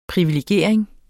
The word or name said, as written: privilegering